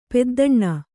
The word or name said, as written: ಪೆದ್ದಣ್ಣ